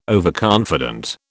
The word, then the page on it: overconfident: British English